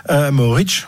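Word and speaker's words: Mohorič: Maoric, on va le, prononcer à la slovène.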